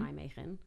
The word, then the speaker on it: Nijmegen: Nijmegen. – Oh, good pronunciation.